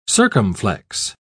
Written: circumflex

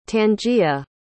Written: tangia

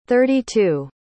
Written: thirty-two

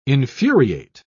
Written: infuriate

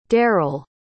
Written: daryll

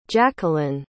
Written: jaquelin

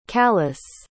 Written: tkalis

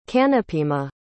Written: Kanapima

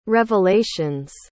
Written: revelations

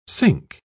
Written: cinque

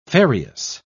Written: ferreous

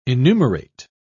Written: enumerate

